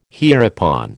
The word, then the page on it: hereupon: American English